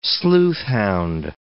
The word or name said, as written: sleuthhound